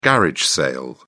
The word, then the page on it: garage sale: British English